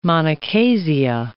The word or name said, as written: monochasia